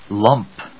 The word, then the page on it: lump: lˈʌmp